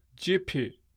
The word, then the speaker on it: JPUG: I've had a chance to start reading that grammar, and as far as I can tell, the pronunciation of the name of this language is Jipi. I do not know where the G comes from in the spelling, but that's what it is.